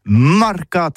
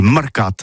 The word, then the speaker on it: marcat: Eh bien, disons à tous, mais le mercat de l'Uni ou le mercat des rebelles, mais en gascogne toulousain, c'est prononcé sur les marcat avec un A.